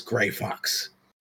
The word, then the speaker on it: Greyphaxe: It's spelled Greyphaxe. And I did that because the English language is that weird.